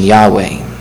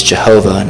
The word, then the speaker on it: Yahweh: In our circles, we prefer the pronunciation Yahweh. Others might use Jehovah.